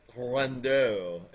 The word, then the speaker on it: Riendeau: we say Riendeau, but somebody on the on the uh, comments was like, "It's pronounced Riendeau," and it's like, yeah, it's French, but hey, you know, Riendeau.